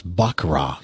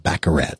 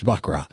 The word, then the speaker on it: baccarat: It's actually pronounced baccarat. Some people call it baccarat. It's French. It's baccarat.